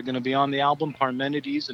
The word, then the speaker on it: Parmenides: Parmenides.